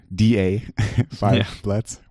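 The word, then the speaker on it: Da 5 Bloods: D.A. Five Bloods. We need to pronounce it that way.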